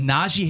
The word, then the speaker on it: Najee: He gets very irritated if you say Najee, which is for, you hear a lot. – Najee. – It's an E. Yeah, say it how it's spelled.